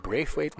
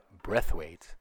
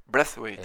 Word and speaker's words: Braithwaite: Niet Braithwaite, maar Braithwaite. – Braithwaite. – Ja. Dat is blijkbaar de Deense uitspraak van zijn.